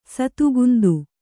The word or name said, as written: ಸತುಗುಂದು